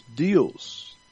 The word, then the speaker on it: Deus: But in Latin, for God, even today, the Latin language, God is Deus, like Zeus, but Deus, D-E-U-S, D-E-U-S, like Jesus, is J-E-S-U-S.